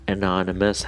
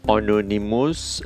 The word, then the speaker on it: anonymous: انانیمس هستن انانیمس آنونیموس